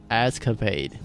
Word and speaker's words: escapade: Like, um, I'll listen listen to movie Escape. How do you say that word? Escape? – Escapade. – Escapade.